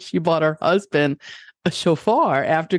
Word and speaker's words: shofar: Are you sure it's pronounced shofar and not chauffeur? – I think it's either way, like tomato, tomato. – Right. – Potato, just potato. Making a dumb joke. How do you say it? – Shofar. – Shofar. It's a shofar.